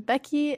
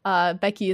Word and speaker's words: Beky: Hi, my name is Becky, a.k.a. Becky Bear on pretty much everything. Becky is spelled without a C, just B-E-K-Y.